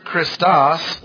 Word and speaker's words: Christos: C-H-R-I-S-T-O-S, accent on the last syllable.